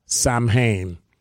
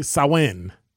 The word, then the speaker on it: Samhain: The Halloween holiday has its roots in an ancient Celtic festival of Samhain. Oh, sorry, Samhain. Pronounced Samhain. It's a Gaelic word, Samhain.